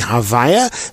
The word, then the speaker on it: Havaya: the way we would pronounce it being Havaya.